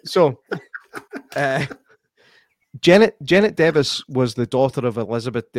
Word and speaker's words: Device: I need to tell myself to say Devis because I keep wanting to say device because that's how it's spelt. And it's also how Derek Acora said it on Most Haunted, but I'm just saying. Right. I'm getting the name device. I'm like, oh, it's Devis, mate.